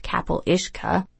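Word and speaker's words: capall uisce: Apart from being nearly universally impossible to pronounce, the name I went with is pronounced Capel Ishka, the main feature of each was a dangerous fairy horse from the water.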